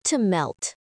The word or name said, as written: to melt